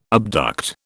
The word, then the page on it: abduct: British English